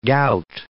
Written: gout

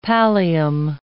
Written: pallium

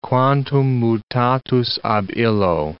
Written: quantum mutatus ab illo

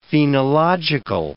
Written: phenological

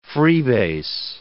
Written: freebase

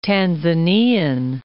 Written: Tanzanian

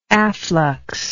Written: afflux

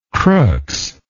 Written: crookes

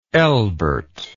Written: elbert

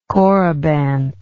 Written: corybant

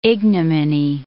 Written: ignominy

